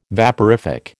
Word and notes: vaporific: American English

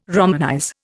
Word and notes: romanize: Indian

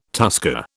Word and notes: tusker: British English

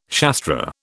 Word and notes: Shastra: British English

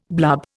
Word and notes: blub: Indian

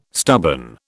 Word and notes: stubborn: British English

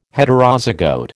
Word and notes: Heterozygote: American English

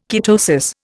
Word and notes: ketosis: Indian

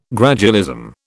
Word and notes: gradualism: British English

